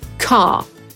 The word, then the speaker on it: car: Na przykład, jeśli uczymy się w brytyjskiej wymowy słowa samochód, no to będzie to car, czyli ostatniej litery R, nie R, tylko R, nie wymawiamy.